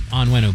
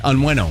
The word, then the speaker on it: Onwenu: It's Onwenu, by the way. – Onwenu.